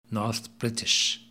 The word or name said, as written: North British